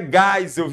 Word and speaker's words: Geisel: Gente, se escreve Geisel, mas a pronúncia é Geisel, viu, meu filhinho?